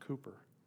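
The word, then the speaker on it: Cowper: His name is William Cooper, pronounced Cooper.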